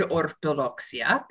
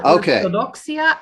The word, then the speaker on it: ortodoxia: Words in Spanish, there are many words in Spanish that end in the letters IA, but mm-hmm. you only pronounce them IA if there's an accent over the I. – Ah, and there is none here. – And there is okay. – none on orthodoxia.